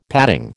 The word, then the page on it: padding: American English